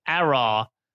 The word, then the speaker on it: Aurore: So I believe it's, I believe Nico pronounced it incorrectly. I believe it's Aurore. Is like your beloved Aaron from Survivor, from Survivor New Zealand, but just with a row at the end.